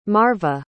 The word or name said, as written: marva